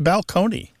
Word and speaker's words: balcony: My favorite change that I love to talk about when Martha and I go and do live events on the road is the word balcony, you know, like this place on a stage that you can look down upon the seats, used to be pronounced balcony.